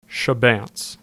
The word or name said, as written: Chebanse